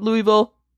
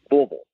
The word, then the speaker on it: Louisville: You have to say it like you have a mouthful of bubble gum, like you're a 9-year-old who just, you know, went too deep on that big league two, and you're just saying Louisville.